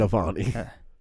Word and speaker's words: Navalny: Because again, Navalny. – Have not seen this. Say it again. Navalny? Is that what it is? Like a novelty? – Navalny.